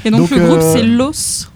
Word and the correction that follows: Loathe: je ne sais pas comment on le prononce, L-O-A-T-H-E, Los, okay. Je pense. Le titre, c'est Gord. – Ok. – Ce qui veut dire breakdown. – Ah, c'est Loathe. L-O-A-T-H-E, Loathe.